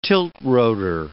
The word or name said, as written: tilt-rotor